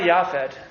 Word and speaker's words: Japhet: Sarah Yafet, in English you would spell it J-A-P-H-E-T.